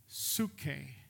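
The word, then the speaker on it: psuche (ψυχή): By the way, the word soul in Scripture is a word that is pronounced this way, psuche.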